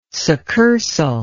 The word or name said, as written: succursal